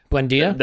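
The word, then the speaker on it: Buendía: So are they going to sell us Winetta or whatever? I can't pronounce the name correctly. Probably not. Not for a price that Arsenal are going to want to pay. – Buendia? – That makes sense. Yeah, Buendia.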